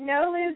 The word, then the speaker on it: Nolose: Am I saying it correctly? – Yeah, you are. – Okay. – Nolose,